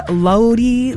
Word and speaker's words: Lodi: Lynn in Lodi.